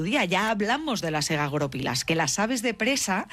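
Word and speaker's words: egagrópilas: Egagoropilas. – Egagoropilas. Vamos a poner el acento en la O.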